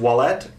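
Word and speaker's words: Ouellet: I believe it's pronounced Ouellette, Cardinal Marc Ouellette in Quebec.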